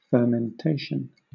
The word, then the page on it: fermentation: British English